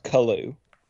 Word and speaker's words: Kalu: Yeah, it's pronounced Kalu.